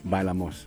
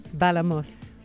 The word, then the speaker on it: Bailamos: Bailamos. – Yeah, Bailamos.